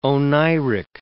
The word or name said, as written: oneiric